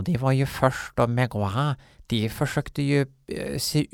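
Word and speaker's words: Mgła: Och med tanke på att Groza även är en Megua, Mgwa, Mogwa, L med ett streck igenom på polska uttalas w. Så det blir mgwa. Mgwa.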